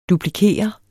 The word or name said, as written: duplikere